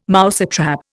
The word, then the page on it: mousetrap: Indian